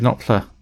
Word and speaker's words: Knopfler: So yeah, he's living in Lewisham or somewhere like that, isn't he, in South London, and ends up with David Knopfler as his flatmate. – Yeah. – Is that right? You're looking like I'm saying this wrong. – No, you're right.